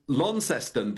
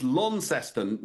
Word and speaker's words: Launceston: I mean, I wonder, now I was told when I was in Launceston that you've got to hit it's Launceston, not Launceston. Is that correct? – Lawn, yeah, I've got to say lawn. It's not lawn there, it's Launceston.